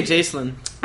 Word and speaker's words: Jacelyn: How do you say this? – I say Jacelyn. – I said Jacelyn, but I feel like... I feel like the spelling is real stupid.